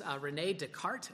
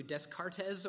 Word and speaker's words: Descartes: Well, this is René Descartes, as opposed to Descartes, or however we might want to butcher the name.